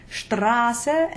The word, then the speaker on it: Straße: Straße and Spaß are both long A sounds, while Nass is short.